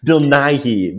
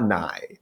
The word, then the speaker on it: Nighy: Bill Nighy, not Nye.